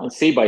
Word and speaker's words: Zeba: No, Zeba is fine.